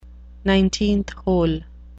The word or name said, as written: nineteenth hole